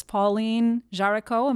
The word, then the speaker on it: Pauline Jaricot: The fact that, you know, your foundress, Pauline Jaricot, am I pronouncing her name correctly? – Yes, that's right.